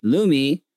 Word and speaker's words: Lumi: Lumi, L-U-M-E, rhymes with gloomy.